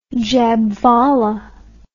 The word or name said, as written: Jhabvala